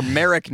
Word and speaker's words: Merrick: They've been friends since their childhood in Merrimack, New York. Or Merrick, New York. Pronunciations are hard.